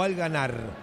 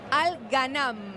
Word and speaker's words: Al Ghanam: Perdóname, ¿es Al Ghanam o Al Ghanar? – No, Al Ghanam. – Ah, con N.